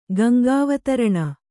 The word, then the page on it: ಗಂಗಾವತರಣ: Gaŋgāvataraṇa